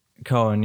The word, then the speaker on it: Coen: Coen, yeah, that's what I thought. I've just been in the Netherlands too long where the way you pronounce O-E is oo, not O-E.